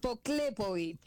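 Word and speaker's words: Poklepović: Poklepovic. – Poklepovic. Terminado en seca. – En C, pero es una C que tiene acento. Es una C croata, entonces se pronuncia como una Ch.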